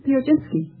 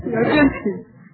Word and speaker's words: Piechocinski: Charlotte, how do you pronounce your name? – Piojinski. – Would you mind spelling it? – P-I-E-C-H-O-C-I-N-S-K-I. – Now pronounce it again. – Piojinski. – Comes out just the same.